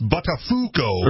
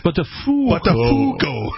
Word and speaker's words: Buttafuoco: Like, it's pronounced Buttafuoco. – Right, yes. Buttafuoco. Yes. In